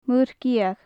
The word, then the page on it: muir gheadh: gaelic